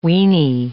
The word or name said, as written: weeny